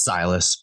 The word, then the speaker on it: Silas: I get all types of pronunciations. I get Silas. I get Silas. It's Silas, but Silas. Close enough.